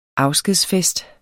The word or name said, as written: AFSKEDSFESTER